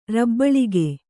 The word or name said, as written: ರಬ್ಬಳಿಗೆ